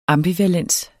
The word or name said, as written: ambivalens